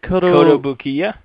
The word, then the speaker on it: Kotobukiya: Kotobukiya.